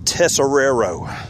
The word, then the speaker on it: Tesoriero: Coach, let me try to pronounce the last name. Field Tesoriero. – There it is.